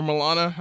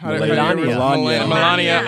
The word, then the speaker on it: Melania: Or Milana? – Melania.